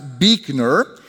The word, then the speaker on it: Buechner: And he says, Buechner is my name. It's pronounced Buechner.